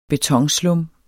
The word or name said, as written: betonslum